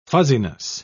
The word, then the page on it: fuzziness: British English